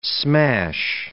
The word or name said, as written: smash